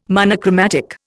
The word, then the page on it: monochromatic: Indian